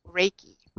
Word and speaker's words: Reiki: So what really is Reiki, though? – Like, okay, well, let's start with a nice Japanese lesson first, Anthony. – Okay. – And we say it Reiki.